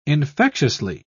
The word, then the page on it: infectiously: British English